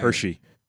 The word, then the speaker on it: Hershey: Morgan, Milton Hersey, also canceled at the last moment and survived to build the Hersey food empire. – Hershey. – Oh, is it a typo? – No, it's Hershey. – Milton Hersey. – That's how you pronounce Hershey.